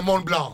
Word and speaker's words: Mont Blanc: Ναι, είναι Mont Blanc. Εντάξει, okay. Ναι, δεν προφέρω λοιπόν, το τελευταίο.